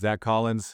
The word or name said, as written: Zach Collins